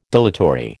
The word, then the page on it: dilatory: American English